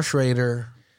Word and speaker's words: Schrader: So, yeah, First Reform, directed Paul Schrader. Is that how you pronounce it? – I believe so. – Paul Schrader, director and screenwriter.